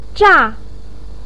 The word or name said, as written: zha4